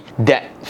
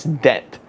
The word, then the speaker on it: debt: debt free. Yeah, I kind of corrected how I pronounce debt. Debt.